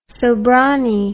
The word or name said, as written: sobranie